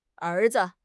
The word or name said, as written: er2 zi5